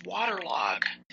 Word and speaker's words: Waterlogue: Another one I really like to use just to be artistic is Waterlog. It's spelled Waterlogue. Waterlog.